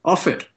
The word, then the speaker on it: Offit: Offit.